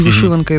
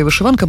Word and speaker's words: вишиванка: За новим правописом наголос подвійний. І вишиванка, і вишиванка. Мені подобається більше вишиванка, тому що цього наголосу точно не знають москалі, тому мені просто подобається вишиванка.